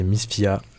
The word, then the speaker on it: Misfy: Et un deuxième de, Bruno Mars, pareil, c'est très subtil, c'est Miss Fia, ça s'écrit M-I-S-F-Y.